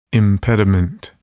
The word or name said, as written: impediment